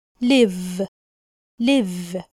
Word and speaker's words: live: كلمة live واللي معناها يعيش بننطقها بالشكل ده live live ف بالفي بحرف الفي أمال يعني إيه كلمة حياة؟ حياة يعني life بحرف الإف ف life مش في يبقى L اي اف اي معناها حياة وبننطقها لايف life L-I-V-E بمعنى يعيش وبننطقها live live live نخلي بالنا ما نقولش live لا نقول live live life